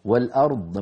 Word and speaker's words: الأرض: يعني ممكن أيضا ننبه على ثقلت في السماوات والأرض وقفنا عليها، والأرض لما نقف عليها يجتمع عند النطق ساكنان، الراء ساكنة والضاد سكنة الوقف، فهذا بحاجة إلى مزيد انتباه أن نبين الراء وأن نبين الضاد. والأرض هكذا نبين الراء لوحدها والضاد